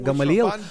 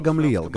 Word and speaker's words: Гамлиел: Итак, прецедент, в котором Раббан Гамлиел, вы, наверное, подумали, что это имя произносится Гамалиел, но корректное еврейское произношение Гамлиел.